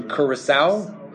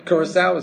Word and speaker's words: Curaçao: So the opportunity arose that there was a Dutch colony in the Caribbean called, and I believe this is the correct pronunciation, Curaçao, mm-hmm. Right? Curaçao, is that? – Yeah.